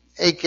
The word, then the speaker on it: eike: It's E-I-K-E. AK, it's pronounced. AK. E-I-K-E.